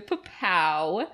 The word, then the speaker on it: Popow: Follow me on Instagram, Christina Popow. – Spell that. – P-O-P-O-W for all those non-Popow knowers.